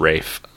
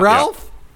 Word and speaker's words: Ralph: No, it's no, Rafe. It's pronounced Rafe. – What? It's not Ralph? – Yeah.